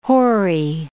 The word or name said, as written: hoary